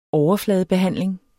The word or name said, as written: overfladebehandling